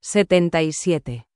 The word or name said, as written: 77